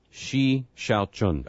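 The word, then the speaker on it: Shi Xiao Chun: S-H-I. We're figuring that's shy. – S-H-I. – I'm writing down S-H-I. – Okay. Here's the last name. – Uh-huh. – It starts with an X, so we have a major problem to begin with, okay? X-I-A-O, Chun. See, and I got the Chun part. It's that first part. – Shi Shao Chun. – Of course it is.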